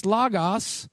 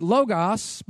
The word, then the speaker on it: logos: Number one, it's logos or L-O-G-O-S, logos. And some pronounce it logos, but it's logos.